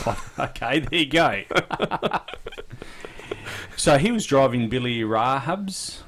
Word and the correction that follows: Rajab: is that how you say it? Billy Rajab. Yeah, Rajab, yep.